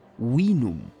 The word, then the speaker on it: vinum: Well, funnily enough, in Latin, we think that a V was originally pronounced like a W. So the Latin word vinum would have been winum, closer to the modern English.